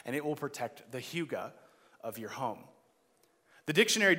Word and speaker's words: hygge: And one of those words was a Danish word that is pronounced hygge.